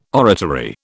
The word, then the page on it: oratory: British English